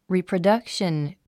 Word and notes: reproduction: rìːprədʌ́kʃən; リィプロダクション

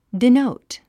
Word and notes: denote: dinóut; ディノウト

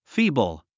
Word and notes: feeble: fíːbl; フィーブル

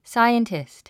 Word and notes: scientist: sáiəntist; サイエンティスト

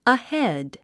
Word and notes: ahead: əhéd; アヘェッド

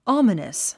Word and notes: ominous: άmənəs; アマナス

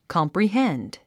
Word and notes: comprehend: kɑ`mprihénd; カンプリヘンド

